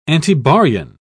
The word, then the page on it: antibaryon: British English